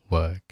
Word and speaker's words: work: 那注意两个单词的发音，一个是走路，一个是工作。走路是把嘴打开，walk，里面塞一个乒乓球，walk。而工作呢是小口 work，注意区别。